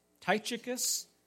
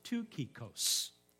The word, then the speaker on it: Tychicus: It's like, for example, Tychicus in the Greek is pronounced Tukikos. Who would say that, right?